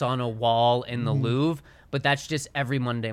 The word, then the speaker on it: Louvre: The Louvre. – It's like Brett Favre.